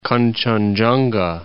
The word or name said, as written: Kangchenjunga